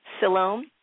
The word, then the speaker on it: Siloam: when I read about, and hopefully this is the right way to pronounce it, Siloam. – Oh, yeah, yeah, absolutely. Yeah, yeah. That's right.